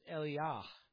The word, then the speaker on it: Eliyah: But these prophets, first of all, Elijah, in English we pronounce it that way, but that's not what it is in the Hebrew language. It's Eliyah. And if you remember that J-A or the J-A-H, it always is Yah like Yahweh. We spell it Y-A-H, but that's not really the way you spell it.